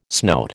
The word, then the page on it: snout: American English